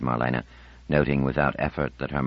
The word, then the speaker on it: Marlena: "Marlena, Marlena, Marlena, three syllables, accent on the second, grown up."